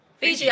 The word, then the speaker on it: Fiji: But I've always loved this place called, I think it's Fuji. Just like, I think they have the water, right? Or is it Fiji? Am I saying it right? Fiji? – Fiji Island? – Island.